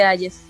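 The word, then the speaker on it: Hayes: Yo lo pronunciaría Ayes también, claro, pero si te lo pronuncian al inglés. – Sí, así lo pronunciamos acá, presidente Ayes. Sí.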